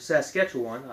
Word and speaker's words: Saskatchewan: Um, but anyway, Swift Runner did end up being arrested, and he was ex- executed at Fort, Sa- Fort Saskatchewan, I hope I'm saying that right, yep.